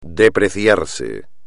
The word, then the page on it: depreciarse: espagnol